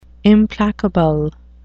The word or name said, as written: implacable